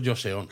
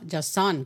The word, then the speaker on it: Joseon: Bueno, yo al menos la J lo hubiera pronunciado al estilo japonés también, hubiera dicho Joseon. – Joseon. No, Joseon, con la O abierta, la segunda O abierta. Ah, pues bueno, el, el, Chosen Ryori, ¿no? Esta cocina Joseon era la, comida de la península coreana.